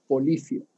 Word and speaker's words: Polyphia: Y en una de esas listas pillé una banda que se llama Polifia, que se escribe con P-O-L-Y-P-H-I-A.